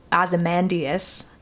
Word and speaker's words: Ozymandias: And after last week's episode, Ozymandias, did I pronounce that correctly? – Yeah.